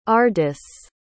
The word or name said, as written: ardis